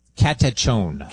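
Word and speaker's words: katechon: Is it catechion? – Catechone. – Catechone, okay? It's from Paul's second letter to the Thessalonians, and it means the restrainer.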